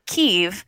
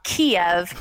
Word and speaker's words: Kyiv: that it's Kyiv, not Kiev. Kiev is the Russian pronunciation, so we should call it Kyiv